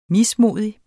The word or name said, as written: mismodig